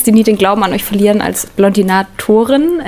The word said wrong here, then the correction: Blondinate: Blondinate, okay, ich habe es nicht richtig ausgesprochen.